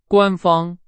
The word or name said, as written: 官方